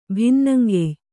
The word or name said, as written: ಬನ್ನಂಗಾಯಿ